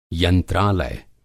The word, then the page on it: यंत्रालय: Hindi